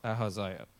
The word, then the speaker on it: Ahaziah: That's how you say it. Ahaziah.